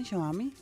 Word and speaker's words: Xiaomi: Con la idea de aminorar los daños de la contaminación que provoca en el país asiático, la empresa Xiaomi, creo que lo pronuncié bien, Xiaomi, ¿sí? – Yo creo que sí.